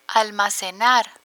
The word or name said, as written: almacenar